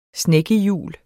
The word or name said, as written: snekke